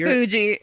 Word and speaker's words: Marfugi: Did I get your name right? – No. – I'm sure if I didn't get mine right when you introduced me. – Marfugi. – Oh, shit. Marfugi sounds yummy.